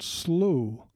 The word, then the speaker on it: Slooh: One of the most popular and one that I have used for several years is called SLOO. It is spelled S-L-O-O-H.